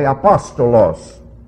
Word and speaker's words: apostolos: It's said a little bit differently, apostolos.